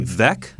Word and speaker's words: Veeck: It's actually pronounced Vec, I believe. – Is it Vec? – Mm-hmm. I know it's okay. – spelled funny, but.